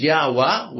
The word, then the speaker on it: Yahweh: Now some say that it could, another pronunciation is Yahweh with an A instead of an E. So that you have the Y and the H followed by AH.